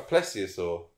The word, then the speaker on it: plesiosaur: A plesiosaur.